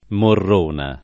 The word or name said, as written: Morrona